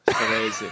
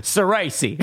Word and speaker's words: Ceresi: Gianmarco Vincenzo Ceresi. That's the full name. – Don't flip the R at the end. Everything else is like completely straight. but Ceresi.